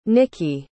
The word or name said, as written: niki